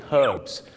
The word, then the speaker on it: herbs: ชาวอังกฤษก็จะออกเสียง herbs ตัว h หอนฮูกชัดเจนมากแต่ชาวอเมริกาเยอะมากครับออกเสียงว่า herbs ไม่มีตัวหอนฮูกกลายเป็นออ่างสมุนไพร herbs กับ herbs